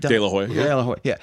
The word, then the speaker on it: De La Hoya: De La Hoya.